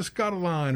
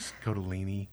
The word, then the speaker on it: Scottoline: Like, oh, you know, Lisa Scottoline or whatever. It's like Scottolini. Like, oh, sorry.